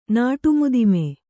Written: ನಾಟುಮುದಿಮೆ

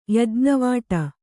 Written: ಯಜ್ಞವಾಟ

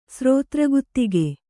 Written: ಸ್ರೋತ್ರಗುತ್ತಿಗೆ

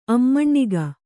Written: ಅಮ್ಮಣ್ಣಿಗ

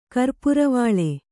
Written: ಕರ್ಪುರವಾಳೆ

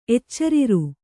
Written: ಎಚ್ಚರಿರು

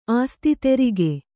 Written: ಆಸ್ತಿ ತೆರಿಗೆ

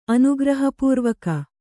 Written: ಅನುಗ್ರಹಪೂರ್ವಕ

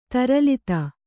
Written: ತರಲಿತ

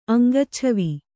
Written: ಅಂಗಚ್ಛವಿ

